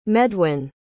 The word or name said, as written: medwyn